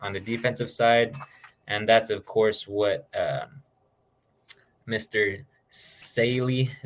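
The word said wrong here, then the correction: Saleh: is that how you say it, AJ? – Sala.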